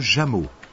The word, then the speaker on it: Jamot: Monsieur Jameau. – Comment ça s'écrit ? – J-A-M-O-T.